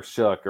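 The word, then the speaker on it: Shough: Well, I think the difference is like, you could run into situations where like, sure, you nail Tyler Shook or whatever his name is pronounced. I apologize. Tyler's family. – No, that's the right way.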